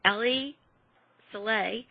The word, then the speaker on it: Ali Saleh: Yemeni President Ali Saleh, that's how I'm pronouncing it anyway.